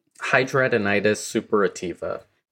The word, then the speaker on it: Hidradenitis suppurativa: Hidradenitis suppurativa.